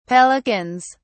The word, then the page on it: pelicans: British English